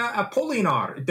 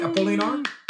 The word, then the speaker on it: Apolinar: So let's bring her onto the podcast, Claudia Apolinar. Did I say that right, Apolinar? – Yes, you did.